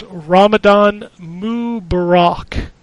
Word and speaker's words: Ramadan Mubarak: Mookie also says, from the pronunciation we obviously butchered last week, it's Ramadan Mubarak. Or Ramadan Kareem.